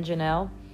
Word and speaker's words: Janelle: And if you want to follow us, just hit us up on Instagram or Facebook, Andrew and Janelle. Andrew is spelled Andrew. A-N-D spelled out, and then my name is spelled J-A-N-E-L-L-E.